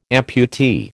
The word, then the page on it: amputee: American English